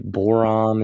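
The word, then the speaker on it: Borom: They also got Larry, I believe Borum is how you pronounce it.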